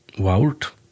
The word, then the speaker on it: waurd: Og stort litt er at agotiskun verda Waurd, W-A-U-R-D, men A-U uttala som Å, så her verda Word.